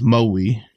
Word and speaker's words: Maui: So kind of more biting down on the on the vowels. Maui.